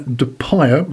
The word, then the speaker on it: DePire: Now, a couple of years ago, we had a lovely lady who was doing the radio, Lorraine DePire, rhymes with fire, and she said, oh, we've got to do this event in the hall.